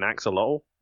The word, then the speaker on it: axolotl: I think I said it right. An axolotl? Yeah, it sounds right.